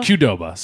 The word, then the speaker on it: Qdoba: Is it Qdoba or Qdoba? – Qdoba, sorry. – No, I don't. I honestly don't know. I've been saying it Qdoba. – We did a whole month on it, and I said it wrong.